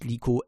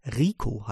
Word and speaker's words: Rico: Der, äh, der weibliche Char Liko heißt eben nicht Liko. Es gibt kein Liko äh, vom japanischen übersetzt. Und der äh, ähm, Name ist tatsächlich vom japanischen übersetzt. Rico heißt das Ganze. Das mag zwar Rico ausgesprochen sein, ich habe es schon mal äh, erklärt, aber ein Liko gibt es nicht. Es gibt ein Rico und geschrieben wird das Rico.